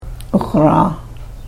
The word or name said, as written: uxraah